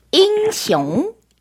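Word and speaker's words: 잉숑 (英雄): 네, 영웅은요. 발음이 굉장히 비슷해요. 잉숑. – 잉숑. – 일성, 이성으로 잉. 발음해 주시면 돼요. 잉숑. – 잉숑. – 잉숑. – 네. 네. Y-I-N-G고요. 그렇죠? X-I-O-N-G.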